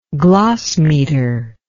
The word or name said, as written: glossmeter